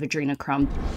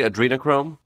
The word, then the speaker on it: adrenochrome: And are we saying it correctly? Adrenochrome? – I mean, that's how they say it in the 1998 video. And if you go, I know you're not supposed to read the video. Are we talking about the Terry Gilliam film? That's how Johnny Depp pronounces it.